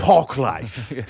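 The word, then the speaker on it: Parklife: Like say it like pork. – Park Life.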